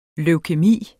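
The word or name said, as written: Leukæmi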